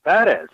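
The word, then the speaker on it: Pérez: So I just pronounced it correctly. I said, Tony Perez.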